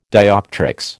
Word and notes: dioptrics: American English

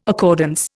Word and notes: accordance: Indian

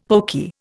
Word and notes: poky: Indian

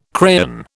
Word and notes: crayon: British English

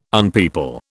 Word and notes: unpeople: British English

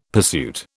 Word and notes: pursuit: British English

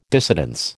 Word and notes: dissidence: American English

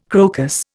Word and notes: crocus: Indian

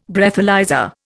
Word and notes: Breathalyser: Indian

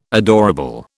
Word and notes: adorable: British English

